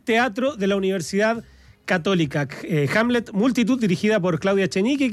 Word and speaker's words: Hamlet: Ahora, y Hamlet, porque no lo dije mal.